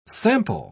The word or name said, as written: sample